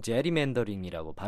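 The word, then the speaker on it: gerrymandering: 미국 사람들은 보통 제리맨더링이라고 발음하죠.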